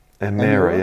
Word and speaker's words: Emira: I'm guessing it's Emira. – Emira, yeah. It won't be a Myra, will it? But it's probably a Emira.